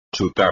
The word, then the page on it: chutar: espagnol